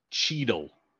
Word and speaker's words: Cheadle: called Cheadle. – Okay. – Like Don Cheadle.